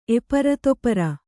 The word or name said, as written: ಎಪರತೊಪರ